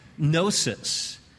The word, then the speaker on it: gnosis: Don't have time to get into it, but maybe you've been confronted, but the first century Gnostics, they would exalt Gnosis. Gnosis, silent G, G-N-O-S-I-S, gnosis, but gnosis is really the term there, and that's the term for knowledge, gnosis, knowledge.